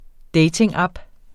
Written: datingapp

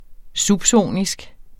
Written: subsonisk

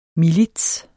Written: milits

